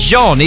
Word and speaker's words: Jean: It's Jean, isn't it? Because it's spelled J-E-A-N. – It's pronounced differently every time because I pronounce it Jean, and a lot of people say Jean. So I'm used to having like Patrick Jean or whatever.